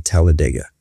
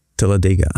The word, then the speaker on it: Talladega: It's pronounced many different ways depending where you are in the States. – Of course. – We as Talladegians would say Talladega. – Talladega.